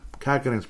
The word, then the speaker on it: Kähkönen: Yeah, Kähkönen's pronounced correctly. Kaapo, it seems like it goes all over the place.